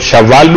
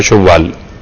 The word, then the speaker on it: شوال: لوگ شوال بھی کہتے ہیں مگر جو اصل تلفظ و شین پر پیش بڑھا جاتا ہے شوال.